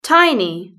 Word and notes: tiny: British English

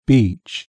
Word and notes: beach: British English

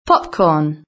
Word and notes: popcorn: British English

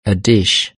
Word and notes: a dish: British English

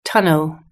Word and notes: tunnel: British English

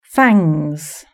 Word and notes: fangs: British English

